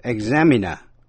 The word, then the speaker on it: examiner: Danh từ examiner, giám khảo, nhấn mạnh vần nhì.